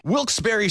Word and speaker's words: Wilkes-Barre: And you're listening to Wilkes Barre Scranton's 97.9X. – Now, I had heard that it was supposed to be pronounced Wilkes-Barre. – Wilkes-Barre Scranton's 97.9X. The only way to pronounce Wilkes-Barre.